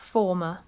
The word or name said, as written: former